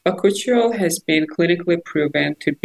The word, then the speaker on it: Bakuchiol: Bakuchiol. – Oh, Bakuchiol.